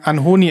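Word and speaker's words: Anohni: Ich habe ja jetzt Anthony gesagt. Man muss ja jetzt ja sagen, Anhoni and the Johnsons. Das ne? ist Anhoni, ja.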